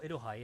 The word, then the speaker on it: Iroha: Oh, Iroha, yeah.